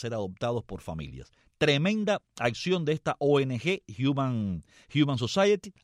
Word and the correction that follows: Humane Society: así se pronuncia, Johnny, ¿verdad? Y entonces, Humane Society, así es como se pronuncia, muy bien.